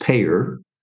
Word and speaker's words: PAEER: And then, but more importantly, in the book, I think, as you know, we introduce a model, a management model, and the acronym is PAEER, pronounced payer, Now, to people in healthcare, they all chuckle when I say that because they think of payer, P-A-Y-O-R, which are insurance companies.